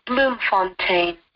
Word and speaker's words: Bloemfontein: Bloemfontein.